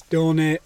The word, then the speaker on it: Doni: But my name is Tony with a D, which is Charlie's just pronounced wrong. So it's Doni.